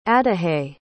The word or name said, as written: adahy